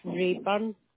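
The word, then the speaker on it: Raeburn: Yes, it was Rayburn.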